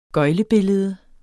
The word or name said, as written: gøglebillede